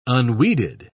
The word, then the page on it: unweeded: British English